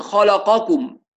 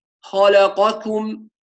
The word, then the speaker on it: خلقكم: خاصة لما تتجاور يعني القاف مثلا مع الكاف في كلمة أو في كلمتين، يعني مثلا خلقكم، هذه الكلمة بنسمع كثير من الناس يقول خلقكم قاكم كأنه بينطق قافين، لا هي قاتو، يبقى نخلص الكاف من القاف، وهذا مهم جدا لما تتجاور الحروف مع بعضها. خلقكم رزقكم ميثاقكم هكذا.